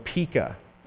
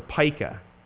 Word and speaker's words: pica: It's really a behavior called pica, or pica. It's pronounced differently different places.